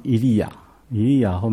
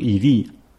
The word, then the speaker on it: 以利: 所以比方以利亚后面 JAH。就耶和华这个名字在里面。那约珥的名字的拼法 JO E L J O 这个可能是耶和华的，像一个简写一样。后面的 E L 是神这个字啊。我们中文常常如果它放在前面，常常把它读成以利，像主耶稣在十字架上，说的一句祷告的话，说我的神，我的神为什么离弃我？